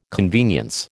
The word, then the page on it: convenience: American English